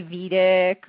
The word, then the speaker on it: Vedic: How do you say it? – It's Vedic, which means Vedic. It's from the Vedas.